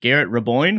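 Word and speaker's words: Garrett Raboin: Stealing away by Motzko's assistant coach, Garrett Reboyne. Am I saying that right? – Yes, correct. Yep.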